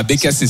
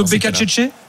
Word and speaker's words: Becacece: Bah, Becasese, c'est alors. – C'est plutôt Becacece ? – Non, ça serait italien.